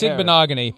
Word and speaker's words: Igbinoghe: Your pronunciation of the name was so bad, it had Igbinoghe? The effect on me. – Like, what is it? – No, Igbinoghe. It's Igbinoghe.